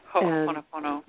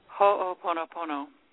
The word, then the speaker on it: Ho'oponopono: Ho'oponopono. – Okay. Would you repeat that? – Ho'oponopono.